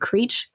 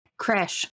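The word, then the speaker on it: crèche: is it Creech? Critch? Critch? C R E C H E? I think it's Critch. Ceremony. I have no idea. Not Catholic. It's pronounced Creche. Kresh.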